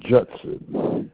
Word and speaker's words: Judson: It's Judson, the last name I just revealed. J-U-D-S-O-N. – J-D-S-O-N.